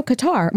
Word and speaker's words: Qatar: My chiropractor is near the, I want to say like, how do you say, is it Qatar? – Oh, Qatar.